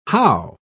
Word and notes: howe: British English

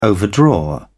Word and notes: overdraw: British English

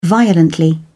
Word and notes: violently: British English

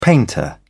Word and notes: painter: British English

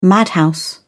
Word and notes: madhouse: British English

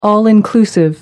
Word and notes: all-inclusive: American English

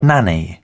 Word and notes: nanny-goat: British English

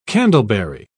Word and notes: candleberry: British English

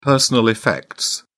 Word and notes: personal effects: British English

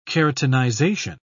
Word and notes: keratinization: British English

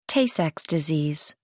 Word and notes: tay-sachs disease: American English

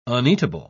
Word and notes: uneatable: British English

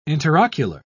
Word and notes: interocular: British English

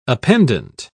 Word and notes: appendant: British English

